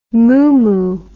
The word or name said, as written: moomoo